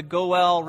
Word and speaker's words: Goel: And the two institutions that we need to know something about are, first, the institution of the Goel Redeemer. The Goel Redeemer. Goel is spelled G-O-E-L, if you're taking notes.